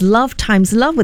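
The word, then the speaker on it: Love × Love: The title pronounced as love times love, with the X representing multiplication.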